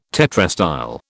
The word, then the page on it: tetrastyle: British English